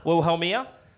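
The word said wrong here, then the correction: Wilhelmina: Will Helmina. Why can you never see the N in that word? – Because I have issues.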